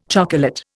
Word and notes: chocolate: Indian

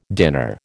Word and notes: dinner: American English

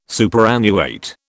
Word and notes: superannuate: British English

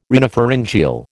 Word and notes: rhinopharyngeal: American English

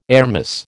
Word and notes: airmiss: American English

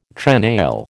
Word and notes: trenail: American English

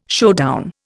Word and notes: showdown: Indian

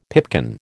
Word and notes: pipkin: American English

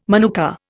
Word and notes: manuka: Indian

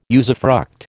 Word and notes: usufruct: American English

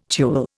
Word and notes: tulle: Indian

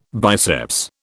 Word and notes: biceps: British English

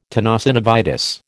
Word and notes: tenosynovitis: American English

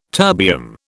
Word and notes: terbium: British English

